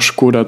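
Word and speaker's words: Škoda: Das wurde von Skoda selbst auch lange Zeit in Deutschland so kommuniziert, eigentlich heißen die aber anders, beziehungsweise es wird anders ausgesprochen. Und wenn du darauf achtest, mittlerweile in einigen Fernsehwerbungen oder eben Video-Ads, sagen sie auch Skoda zu sich selbst.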